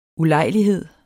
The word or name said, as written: ulejlighed